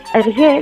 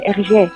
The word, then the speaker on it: Hergé: permutándolas a RG, de manera que pronunciadas en francés sonase RG. Sin embargo, la licencia creativa de Hergé terminó con este detalle,